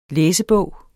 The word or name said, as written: læsebog